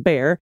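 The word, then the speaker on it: Bexar: Dana's pronunciation of Bear County, pronounced Bear, inspired me to finally write in with some Central Texas town twisters.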